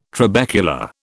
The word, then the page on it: trabecula: British English